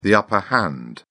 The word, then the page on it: upper hand: British English